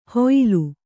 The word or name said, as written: ಹೊಯ್ಯಲು